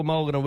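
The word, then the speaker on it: Mulgarda: Our local Mulgara word today is Mulgara.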